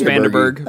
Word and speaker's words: Vandenberg: No, it's just Vandenberg. – That's what I thought. – Well, that's how it's spelt, so I'm pronouncing it phonetically.